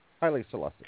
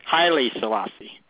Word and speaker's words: Haile Selassie: Also, I'm going to say this name wrong probably, but it's, is it Haile Selassie? – Haile Selassie. – Haile Selassie. Okay. Yeah.